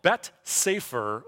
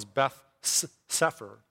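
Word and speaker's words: Bet Sefer (בית ספר): At age 5, when American kids nowadays go to kindergarten, Jewish children would enroll in what's called Bet Sefer, or it's otherwise pronounced as Beth Sefer.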